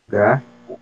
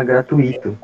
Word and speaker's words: gratuito: Muita gente fala gratuito, com, no caso, a sílaba tônica seria no i, mas na verdade é gratuito. Então a sílaba tônica logo u ele vai ser a oral e o i vai ser a semivogal.